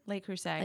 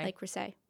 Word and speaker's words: Le Creuset: Like La Crusette. Did I say it right? – Le Creuset. – Le Creuset. – Yeah. – I knew I didn't say it right.